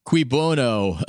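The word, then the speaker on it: cui bono: It's just qui bono. Is that how it's said? Who benefits? Yes, continue.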